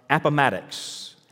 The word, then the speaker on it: Appomattox: And Mary, you're from Virginia, so maybe I'll get this pronunciation right. Appomattox. Appomattox.